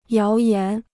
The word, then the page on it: 谣言: yáo yán